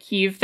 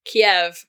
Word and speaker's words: Kyiv: The K-Y-I-V spelling, pronounced right. Kyiv, or something like that, versus Kiev, K-I-E-V, yeah. – Okay, so it's closer to an English F sound than a V sound?